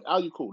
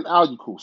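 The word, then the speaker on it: Aiyuk: And Al Yuku. That's how you pronounce his last name. Brandon Ayuk, sorry, six receptions, 119 yards.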